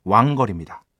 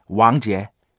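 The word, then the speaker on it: 왕지에 (王杰): 우리식 한자 발음으로는 왕걸입니다. 그런데 중국어로는 왕지에, 왕지에 그리고 어, 곡 제목은 영어로 하면 Forget About You, Forget About Me.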